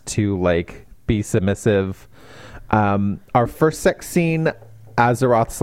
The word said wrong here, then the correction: Azrath: like, it's Azeroth, right? – Yeah. – Azrath. – Azrath. Sorry. Oh, I said that wrong.